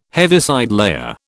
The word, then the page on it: Heaviside layer: British English